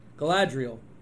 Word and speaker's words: Galadriel: And Elrod, Elrod, Galadriel.